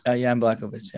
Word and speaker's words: Jan Blachowicz: Well, listen, I think, you know, as we know, Jan Blachowicz. Is that how you say his name? – Yeah, yes. Jan Blachowicz, yeah.